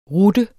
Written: rutte

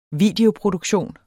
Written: videoproduktion

